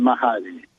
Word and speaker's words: Mahdi: By the way, it's the M-A-H-D-I is pronounced Mahdi. The H is silent.